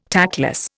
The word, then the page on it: tactless: Indian